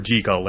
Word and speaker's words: Jekyll: One of the things they referred to was Dr. Jekyll as opposed to Dr. Jekyll, because the original pronunciation that Stevenson wanted was actually Dr. Jekyll, and he spent the rest of his life telling people to pronounce it Dr. Jekyll.